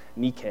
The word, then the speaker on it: Nike: We pronounce it Nike. It's actually pronounced Nike. It means victory.